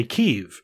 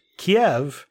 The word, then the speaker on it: Kyiv: And also the pronunciation of Kyiv, which he gave the actual Ukrainian pronunciation, which was something it sounded had a, like a U sound in it. It was like Kyiv. But he's like, that's really difficult for English speakers to pronounce. So please just say Kyiv, because Kiev is the Russian pronunciation of our capital city.